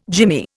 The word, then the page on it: jimmy: Indian